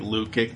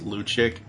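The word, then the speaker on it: Lukić: It looks like Lukic, but I think it's pronounced Luchik.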